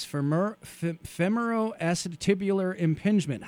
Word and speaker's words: femoroacetabular impingement: Now, you referred to it as FAI, and I will try to pronounce this, femoroacetabular impingement. How did I do? – Perfect.